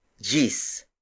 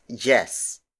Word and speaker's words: diyes: Gis is spelled as D-I-Y-E-S. It means 10. In the Tagalog language, the D-I-Y combination is pronounced as J. Technically, this word should have been pronounced as Jess. But for some reason, Tagalog speakers find it more convenient pronouncing it as Gis instead.